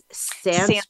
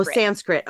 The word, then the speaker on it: Sanskrit: Sanskrit. – Oh, Sanskrit.